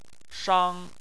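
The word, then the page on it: shang: tone 1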